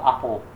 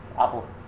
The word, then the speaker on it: Apple: Inclusive la compañía Apple Records se llamaba, ellos lo pronunciaban como Apple, en inglés, Apple.